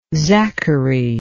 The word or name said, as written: zachary